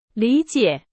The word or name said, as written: 理解